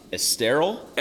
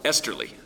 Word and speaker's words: Esterly: Now, I'm a writer by nature, so I only need to know how to spell it, but is it Alex Esterl? – Esterly.